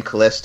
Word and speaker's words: Caless: You can find me on Facebook as my real name, Kales Davis, and that's spelled C-A-L-E-S-S.